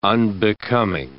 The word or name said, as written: unbecoming